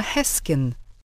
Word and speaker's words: Heskin: I'm Emma Heskin. – Spell it, please. – H-E-S-K-I-N.